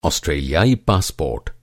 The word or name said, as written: ऑस्ट्रेलियाई